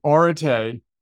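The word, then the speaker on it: orate (ἀρετή): To step back, so orate is how you pronounce that funny-looking Greek word on my t-shirt and on the book,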